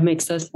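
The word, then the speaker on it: Maegzter: Is that how you pronounce it? – Yeah, Mixter's fine. – Mixter. So it's M-A-E-G-Z-T-E-R on TikTok.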